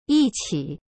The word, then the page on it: 一起: yiqi